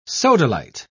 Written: sodalite

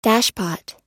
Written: dashpot

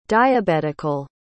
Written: diabetical

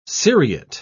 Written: seriate

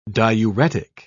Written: diuretic

